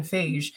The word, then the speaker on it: Feige: Is it Fage? How do you pronounce it? Is it Kevin Fage? I'm going to pronounce it like beige. Kevin Feige is what happens when you give a male feminist a little bit of power and millions of dollars.